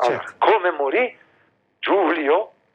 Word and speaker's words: Giulio: No, hai sbagliato. Giulio, forse. – Non è Gaulio Cesare? – No, no, Giulio. – Lo so, ma in America la I si legge AI, no? – Ah, quindi Gaulio? Dite Giulio Cesare? – Sì, qui in Italia.